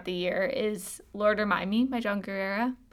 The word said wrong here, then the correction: Guerra: Is that how you say his name? – I'm John, probably saying it wrong. I think it's Guerra.